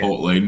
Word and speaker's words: Chipotle: It's how do you say it? – Chipotle, not Chipotle.